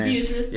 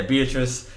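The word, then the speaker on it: Beatriz: Stephanie Beatrice, I'm not sure how you pronounce her last name. – Beatrice. – Yeah, Beatrice.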